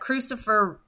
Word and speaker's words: crucifer: Um, and I think it's interesting that crucifer rhymes with Lucifer.